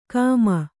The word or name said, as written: ಕೇಮ